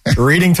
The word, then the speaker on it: Reading: I got to give him the correct pronunciation. – Reading terminal.